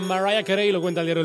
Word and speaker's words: Mariah: María, pero no se pronuncia María, sino Mariah.